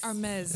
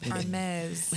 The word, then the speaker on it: Hermes: Ata jina lake tu ni Hermes. Oh, sorry, Hermes. The H is silent. Hermes.